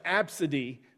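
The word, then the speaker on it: Abcde: And it's like actually pronounced Absidy, but it's A, B, C, D, E.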